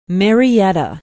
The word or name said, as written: marietta